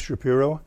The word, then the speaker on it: Shapiro: Okay, my name is Kenneth Shapiro. And it's spelled S-H-A-P-I-R-O.